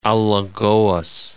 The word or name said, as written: Alagoas